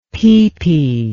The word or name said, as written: peepee